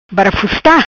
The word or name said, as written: Barafusta